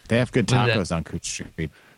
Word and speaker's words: Couch Street: This week's word is pronounced Cooch Street. Yes, you heard that right, Cooch Street. I have no fucking explanation for why Couch Street is pronounced Cooch Street, but that's how we roll here in Portland.